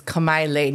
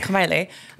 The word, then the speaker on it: Kamaile: Well, first of all, it's Kamaile. Nobody says it like that, though. You know, local people just don't want to pronounce it. – Kamaile. – It's Kamaile.